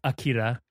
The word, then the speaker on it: Akira: But right now, let's get into our main discussion for the evening, which is 1988's anime classic Akira, pronounced Akira.